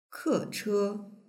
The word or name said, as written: keche